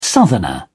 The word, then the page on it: southerner: British English